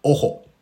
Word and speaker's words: OHO: フィンランドで漫才大会やってるとか、で、その漫才コンテスト優勝したオホ、ね、 OHO と書いてオホと読みますけれども、彼らのネタ動画であるとか、なんか写真なんかはね、見たことがある人もいるんじゃないかと思っております。